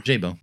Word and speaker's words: Szaybo: Szabo. S-Z-A-Y-B-O is Szabo. – Szabo.